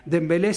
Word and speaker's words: Dembélé: Dembélé sí. – Dembélé. – Mbappé sí. Due, porque hay una otra conformación con otra vocal. Pero vos la sentás con Mbappé o Dembélé.